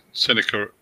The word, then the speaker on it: Sennacherib: And so some of the stuff that was going on here was that this king of Assyria, Sennacherib, or I'm not sure how you pronounce that, but anyway, Sennacherib.